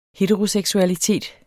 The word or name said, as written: heteroseksualitet